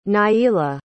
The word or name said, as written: Niela